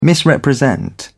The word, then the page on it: misrepresent: British English